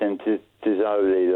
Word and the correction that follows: Zoll: last week, but it's Zoll, about Christoph Zoll apparently.